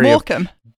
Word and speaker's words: Morecambe: Morecambe. – Yeah, Morecambe. Okay, yeah, I'm saying it wrong.